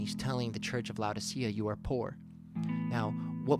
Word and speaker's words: Laodicea: But he also said to the church of Laodicea, and I believe it's pronounced Laodicea, L-A-O-D-I-C-E-A, Laodicea.